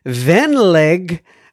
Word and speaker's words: venlig: May, and then Venlig, silent G. Oh, so Venlig. So May Venlig Hilsen.